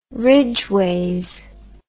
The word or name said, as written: ridgeways